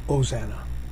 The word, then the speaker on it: Hosanna: Or in English, it would be O-S-A-N-N-A. For some reason, we put a H sound on it. I don't think Greeks do that. They call omega the long O, Hosanna. We like to put an H in front of it, a silent H. Well, not so silent. Hosanna is not silent.